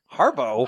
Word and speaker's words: Harbaugh: Harbo. But it's spelled Harbaugh? – But it's spelled H-A-R-B-A-U-G-H. Junior. Greg Harbo Jr. Harbo. – That's not legal. Letters have meaning. – G-H does not have meaning in the English language. Right. We cannot pronounce that letter.